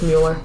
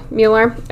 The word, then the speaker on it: Mueller: The Jameses thus believe that Mueller, I don't know why I'm having such a hard time pronouncing that. – It was just Mueller. – Mueller, okay. Was